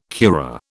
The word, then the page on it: curare: British English